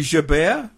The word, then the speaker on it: Gerbert: I since have learnt that I believe Gerbert is actually Gerbert. Is that right? – Well, if you want to use a French pronunciation, that I think would be correct, but he's probably a well enough known character, right? – At least among historians, to be allowed to use an English pronunciation of his name.